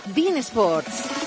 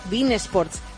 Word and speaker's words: beIN Sports: Ya ha llegado a España Bein Sports. Que se pronuncia así, ¿eh? Bein Sports.